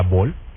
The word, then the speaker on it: Bohol: Es a la isla Bol. La isla Bol es un diminutivo que degeneró por ahí de Bolaños. Entonces se le quedó Bol. Y se escribe B-O-H-O-L. Bol.